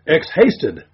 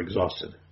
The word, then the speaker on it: exhausted: I have seen this word in print, but never having heard it spoken, assume it is pronounced ex-hasted. All this time, I have been ex-hasted now and then, never exhausted.